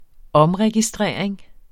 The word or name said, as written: omregistrering